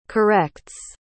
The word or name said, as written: corrects